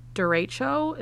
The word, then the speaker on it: derecho: And we actually just missed a huge derecho. Is that how you say it? – Yeah.